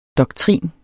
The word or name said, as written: doktrin